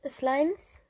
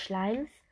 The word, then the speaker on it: Slimes: So, hier habe ich einmal Rang DW Schleim, Slimes. So wird ausgesprochen. Ähm, also Slimes, nicht Schleims.